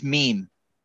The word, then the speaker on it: meme: Oh, you say it's meme. Sounds like M-E-E-M. Meme.